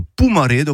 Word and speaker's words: Pomarède: Une pommerée, ça veut dire en occitan, une pomarède ou Pomarède en prononciation francisée.